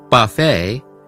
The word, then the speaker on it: buffet: Entonces, recuerde en francés, muchas palabras terminan con una T muda, lo que significa que la T final no se pronuncia. Por ejemplo, en palabras como ballet, cabaret o buffet, la T al final es completamente silenciosa.